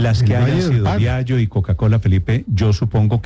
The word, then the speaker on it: Diageo: Diageo. No se dice Diageo.